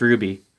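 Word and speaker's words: RWBY: It's R-W-Y-B. Yeah. – Oh. – But it's pronounced Ruby.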